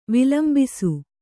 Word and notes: ವಿಲಂಬಿಸು: vilambisu; kannada

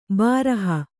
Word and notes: ಬಾರಹ: kannada; bāraha

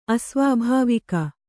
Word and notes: ಅಸ್ವಾಭಾವಿಕ: Kannada

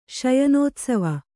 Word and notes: ಶಯನೋತ್ಸವ: śayanōtsava

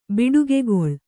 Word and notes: ಬಡಿಗೆಗೋಲು: baḍigegōlu

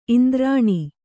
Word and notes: ಇಂದ್ರಾಣಿ: indrāṇi

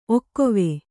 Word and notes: ಒಕ್ಕೊವೆ: Kannada

